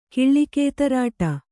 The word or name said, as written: ಕಿಳ್ಳಿಕೇತರಾಟ